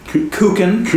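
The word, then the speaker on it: kuchen: You got the Kuchin? – Kuchin. Yeah. Harder consonants, yeah.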